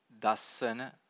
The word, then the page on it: dassana: Pali